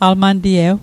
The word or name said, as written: alman'tiew